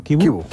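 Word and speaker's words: Chivu: En Tuttosport, Kibu, el técnico del Inter, se pronuncia así, ¿no? Kibu.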